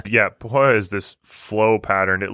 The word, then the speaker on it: pahoehoe: It's spelled P-A-H-O-E-H-O-E, and it's pronounced Pohoihoi, and it's a flow texture.